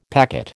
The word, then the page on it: packet: American English